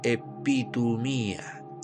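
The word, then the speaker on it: epitumía (ἐπιθυμία): Y es una palabra griega que se pronuncia epitumía.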